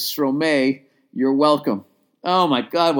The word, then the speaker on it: Stromae: Check out an artist named Stromayor. That's how you say it, Stromayor. No. My God, I'm an idiot. Oh, my God, I'm a fucking idiot. It's Stromae.